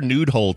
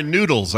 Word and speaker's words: noodles: Oh, I'm sorry, noodles. I said it wrong. Noodles. – Yes. – Not nude hole.